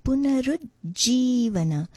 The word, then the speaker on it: Punarujjivana: The ru is a little bit of a slightly rolled syllable, and there is a slight stress on the third syllable, the ru. Punarujjivana.